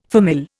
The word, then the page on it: famille: Indian